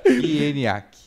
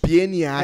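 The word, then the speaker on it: Pieniak: Pieniac. Pieniac. Ah, eu falo certo. – Pieniac. – Eu falo certo. Caraca, meu. É exatamente é como se escreve.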